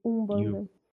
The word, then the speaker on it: Umbanda: Ubanda? – U-M-B-A-N-D-A, Umbanda.